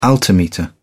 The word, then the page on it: altimeter: British English